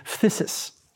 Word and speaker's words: phthisis: At first, the oldest term for it is the unbelievably wonderful but hard to pronounce Greek word phthisis, or phthisis probably originally, P H T H I S I S.